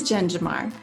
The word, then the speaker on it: Jen Jamar: Is it Jen Jamar? – It is Jen Jamar.